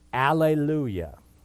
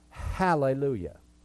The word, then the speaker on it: Hallelujah: Also, there is a word, Alleluia. It starts with an alpha. It starts with an A. And yet it has that rough breathing mark, so it should always be pronounced Hallelujah.